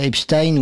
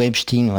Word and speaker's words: Epstein: Oui, non, mais alors on lui reproche d'avoir dit Epstein. Or moi, je l'entends ce mot-là, enfin je l'entends prononcer comme ça, mais tout le temps, dans toutes sortes dans les médias. Enfin, Bah oui, c'est la norme.